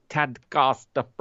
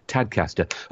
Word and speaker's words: Tadcaster: Get in touch with her via the forum and have a rocking Tadcaster Friday. Oh, look, I pronounced it wrong then. Oh, I'm supposed to be from Yorkshire. Tadcaster. A rocking Tadcaster Friday.